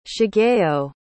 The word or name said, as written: shigeo